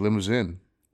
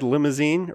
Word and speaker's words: Limousin: I said limousine, or I was going to say pronounce it as limousine. – I thought it was limousine. – Yeah, but it doesn't have the E at the end.